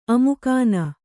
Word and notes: ಅಮುಕಾನ: kannada

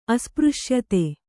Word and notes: ಅಸ್ಪøಶ್ಯತೆ: kannada

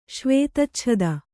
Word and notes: ಶ್ವೇತಚ್ಛದ: kannada

